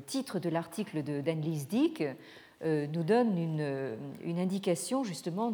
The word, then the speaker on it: Dyck: de d'Annelise Dick, ça s'écrit D-Y-C-K, euh, qui est intitulé La Chine hors de la philosophie.